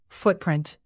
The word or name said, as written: footprint